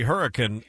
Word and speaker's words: Hurricane: Yeah, I was going to say, you can know immediately if folks aren't around from around here when they say Hurricane Valley. It's definitely Hurricane.